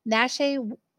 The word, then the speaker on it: Naschy: And it's the and this is there it says there are 12 Paul Nashte I'm pronouncing this wrong. Naschy werewolf movies, but there can only be one on the list.